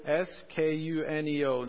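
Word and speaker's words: proskuneo (προσκυνέω): It's proskuneo.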